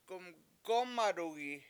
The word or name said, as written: gumgOmadugi7